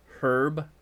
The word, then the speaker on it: herb: A correct pronunciation of herb is herb with a audible H.